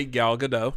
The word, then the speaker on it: Gadot: You pronounce the T. – He refers to her as Gal Gadot. – She does too. – I say Gal Gadot. – You're wrong. She pronounces the T.